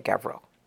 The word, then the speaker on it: Gavril: Gavril. And how do you pronounce that, by the way, Gavril? – Just how you just did, pal.